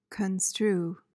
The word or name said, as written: construe